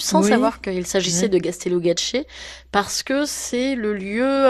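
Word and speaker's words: Gaztelugatxe: Alors c'est Gastélugatché. Gastélugatché,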